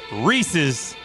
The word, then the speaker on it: Reese's: And number 1, it does not rhyme with feces. It is Reese's Peanut Butter Cups at number 1.